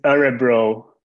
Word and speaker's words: Örebro: it's Örebro.